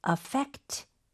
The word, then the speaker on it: affect: Affect 목적어. 여기서 스펠링 주의하셔야 돼요. affect입니다. – 이거는 동사로 쓰게 된 거네요. – 네, 그렇죠? – 맞아요. – Right. So FE에 강세를 주면서 affect 뭐 someone something, right?